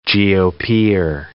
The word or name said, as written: GOPer